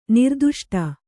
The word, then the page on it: ನಿರ್ದುಷ್ಟ: kannada